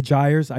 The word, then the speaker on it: gyre: And the gyres, I actually hadn't heard that word before.